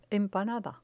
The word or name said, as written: empanada